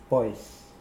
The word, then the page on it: pwoas: Mokilese